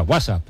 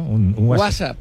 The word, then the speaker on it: WhatsApp: No, peor aún, con acento en la primera. WhatsApp, ¿no? – WhatsApp.